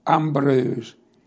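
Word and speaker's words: Ambrœus: Sant'Ambrose a sa scrivere senza l'accento fra Sant e l'Ambrose, e Ambrose è A-M-B-R-O-E-U-S, Ambrose, e inici a sa scrivere.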